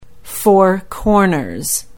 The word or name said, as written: Four Corners